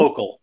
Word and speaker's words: Hochul: Hochul. – Hochul. Yeah. – Okay. – Like yokel. It rhymes with yokel.